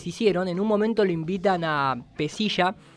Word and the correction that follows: Pelliza: que es el siempre lo pronuncio mal, ¿cómo es, maestro? – Pelliza. – Pelliza, exactamente. Que